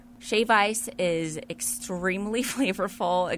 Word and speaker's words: shave ice: It's not shaved ice. – No, it isn't. – Now we're talking about pronunciation on this podcast. – Yeah. So let's be correct. Let's be articulate. It's shave ice.